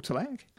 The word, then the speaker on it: talaq: But have you ever heard of triple tillac? – No. – So tillac is spelt T-A-L-A-Q.